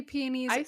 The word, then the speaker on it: peonies: I say peonies and then my mom gets mad at me, says that I say it wrong. It's peonies.